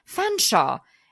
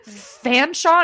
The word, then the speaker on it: Fanshawe: Her name is Jane Shaw, and her and Wilma's middle name was Jane, and her name was pronounced Fanshawe, and it is Featherstone's Haw, which he does point out, which it must be true, but that's fucked up. Fanshawe, and it's spelled Featherstone's Haw.